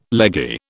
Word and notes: leggy: British English

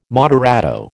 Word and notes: moderato: American English

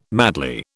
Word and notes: madly: British English

